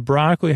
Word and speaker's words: broccoli: Oh, but broccoli, how do you spell it? For the love of Barbara Broccoli. It's B-R-O-C-C-O-L-I. And it's from the Italian. It features a double C to make a K sound, and you might want a Y or an E-E to make that Lee sound, but it's just an L-I.